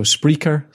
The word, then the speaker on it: Spreaker: So Spreaker, spelt with an R, S P R E A K E R.